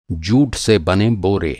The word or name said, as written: जूट